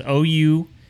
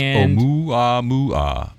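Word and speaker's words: Oumuamua (ʻOumuamua): It's Oumuamua. – Pronounced Oumuamua.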